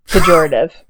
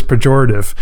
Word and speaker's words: pejorative: Is that the word? How you say it? – Pejorative. – The word is pejorative.